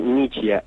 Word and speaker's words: Michia: Se dice Michia, M-I-C-H-I-A. Es con una sola C de casa.